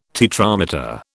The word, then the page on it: tetrameter: British English